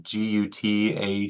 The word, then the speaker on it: gutache: It's spelled GUTACHE, and every time I looked at it, it's like, what is that? You know, what is it? And then of course you break it apart, gut ache.